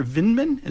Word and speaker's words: Vindman: Alexander Vindman, is that how you pronounce it, Tony? – Yes, correct.